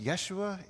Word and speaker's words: Yeshua: In Hebrew, it's pronounced Yeshua.